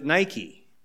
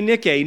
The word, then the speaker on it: Nike: It's the Greek word Nike, but you pronounce it Nike because that's what the athletic shoe company has told you you're supposed to call it. But it's actually Nike.